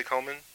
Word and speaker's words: Komen: Am I saying that correctly, Komen? – Yeah.